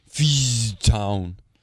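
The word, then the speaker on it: Fischtown: Is the S? What? I wonder what's silent. The Fish S or the town. – C? Fishtown. – Fishtown Penguins. – Yeah. – Say it like that.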